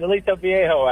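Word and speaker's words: Aliso Viejo: He's out in, see if I say this right, Aliso Vallejo, California. Did I say that right? – Yes, you did. Aliso Vallejo, actually.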